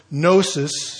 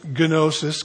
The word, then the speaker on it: gnosis: Gnosticism comes from that Greek word gnosis. Some people pronounce it gnosis because it's spelled G-N-O-S-I-S.